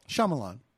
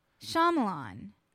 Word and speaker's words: Shyamalan: How do you say it? – Shyamalan. – Shyamalan. – Yes. Because there's a Y in it. – Shyamalan. – Yes. Shyamalan. – Am I saying it right? – You are saying it right.